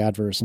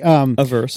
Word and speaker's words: averse: I am super scared of risk, and I always pronounce the word wrong. I always want to say adverse, and that's not right, is it? Anyway, averse. – Thank you.